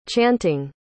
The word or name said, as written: chanting